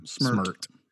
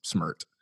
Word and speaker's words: Smert: Smert. – It looks like it should be Smart, but there's no A. It's just Smert.